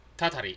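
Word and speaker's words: Tatari (祟り): And as much as it is an interesting concept of, I want to say tatari, please remind me again if I'm saying that right. – Cursing? – Yes. – Tatari, yeah. – Tatari, yeah, thank you.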